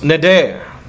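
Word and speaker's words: neder (נדר): The word vow means, it's pronounced neder.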